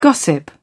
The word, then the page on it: gossip: British English